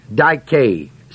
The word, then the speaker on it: dike: The word just is the Greek word spelled D-I-K-A-I-O-S. Dikaios, it's an adjective form. Its basic root form is the word dike, spelled D-I-K-E, long E. That word means right, R-I-G-H-T.